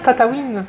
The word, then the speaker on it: Tataouine: C'est sûr, ça, ça, se prononce Tatawin